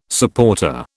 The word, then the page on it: supporter: British English